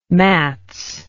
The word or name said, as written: mats